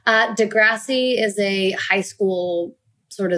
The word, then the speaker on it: Degrassi: It's Degrassi, but Degrassi. Americans say Degrassi for some reason.